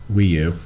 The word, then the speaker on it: Wii U: Wii U.